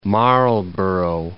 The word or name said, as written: Marlborough